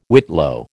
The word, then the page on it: whitlow: American English